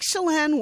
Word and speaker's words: Chelan: Oh, that's the other one that I did phonetically. Lake Chelan, Washington, because I never would have pronounced that correctly. – No, it's C-H-E-L-A-N. I would have been like Chelan.